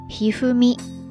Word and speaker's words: 一二三: 大家没有听错哈，这个人的名字特别的好玩，叫做加藤一二三一二三，在这读作 Hifumi。